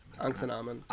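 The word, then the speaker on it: Ankhesenamun: That's how they sort of pronounce it in the 1999 Mummy film. – They say Anaximamon. What do they say? – Anaximamon, or something more along that lines, instead of Anxinamon. – Anxinamon, right.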